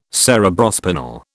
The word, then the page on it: Cerebrospinal: British English